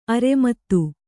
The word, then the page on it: ಅರಿಮೇದ: arimēda